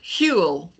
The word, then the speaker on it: Huel: And he is the founder and chief marketing officer of Huel. I think I'm pronouncing that right. – Human Fuel, Huel, yes. – Huel, okay.